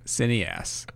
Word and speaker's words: cineaste: Yes, cineast. That's yes, that's it. – I'm gonna start using that. – It's spelled like cineaste.